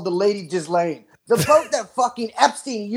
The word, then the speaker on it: Ghislaine: It's Ghislaine, but it sounds better to say Ghislaine.